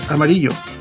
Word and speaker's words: Amarillo: She is also the past president of Add to Amarillo, which I insist should be pronounced Amarillo.